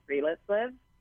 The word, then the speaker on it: Friluftsliv: For example, in Norway, I studied this philosophy called Friluftsliv. It's spelled F-R-I-L-U-F-T-S-L-I-V.